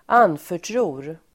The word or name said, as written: anförtror